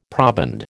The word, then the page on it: proband: American English